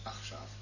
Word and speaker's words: Achshav: Achshav.